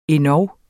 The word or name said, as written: endog